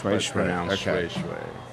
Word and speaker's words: Shweshwe: It's spelled like shushu. – But that's pronounced Shweshwe.